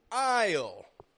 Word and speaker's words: aisle: It's spelled A-I-S-L-E. But once more, your senses are not deceiving you. Your ears are hearing me correctly. Aisle, A-I-S-L-E, is sounding the exact same as I-S-L-E. Aisle, aisle, same sound, exact same pronunciation. Aisle. This spelling, A-I-S-L-E, does not refer to a landmass.